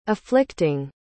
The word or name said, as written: afflicting